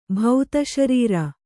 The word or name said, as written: ಭೌತಶರೀರ